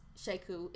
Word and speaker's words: Sheku: Sheku. It's spelled S-H-E-K-U.